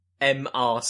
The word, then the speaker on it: MR: Yeah, that's where they got MR speed squared. It is pronounced. I can confirm it's pronounced Mr., even though it's M.R in capitals.